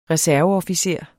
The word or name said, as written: reserveofficer